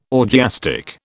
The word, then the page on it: orgiastic: British English